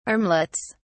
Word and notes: armlets: British English

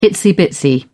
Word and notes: itsy-bitsy: British English

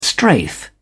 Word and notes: strafe: British English

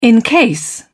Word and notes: encase: British English

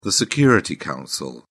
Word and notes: security council: British English

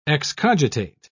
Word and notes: excogitate: British English

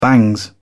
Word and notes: bangs: British English

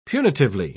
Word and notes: punitively: British English

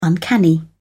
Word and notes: uncanny: British English

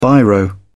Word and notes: biro: British English

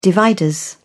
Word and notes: dividers: British English

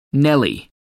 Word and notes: nelly: American English

